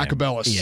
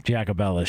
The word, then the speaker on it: Jacobellis: Jacobellis. – Yeah, Jacobellis.